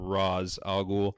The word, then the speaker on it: Ra's al Ghul: Same thing with Ra's al Ghul, or in that movie, how they pronounce his name, Ra's al Ghul.